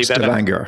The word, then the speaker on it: Stavanger: And am I pronouncing the name of the city correctly? – Stavanger is probably Stavanger.